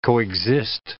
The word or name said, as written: coexist